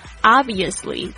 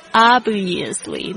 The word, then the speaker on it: obviously: Obviously 注意一下它的发音，它是 O-B-V-I-O-U-S-L-Y Obviously 那个 B 的这个声音是因为后面它接的同样也是辅音的这个词这个字呢，所以就会听起来好像 B 的声音不太听得到了。